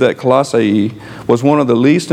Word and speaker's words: Colossae: So the word Colossae is how it's pronounced, by the way. So if I slip, I've always said Colossae, but it's Colossae.